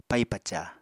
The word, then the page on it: pai-paca: Apatani